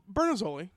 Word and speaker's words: Bernazzoli: The Bernazzoli. – Oh, I said it right. – Yeah. – Okay. – Pretty much any Italian dish that ends in oli.